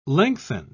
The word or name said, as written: lengthen